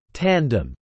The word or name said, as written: tandem